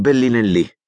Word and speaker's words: Bellinelli: Suo fratello restò in Francia, dove vive tuttora con la famiglia, quella dei Bellinelli, con la L conservata e con l'accento tonico sulla I.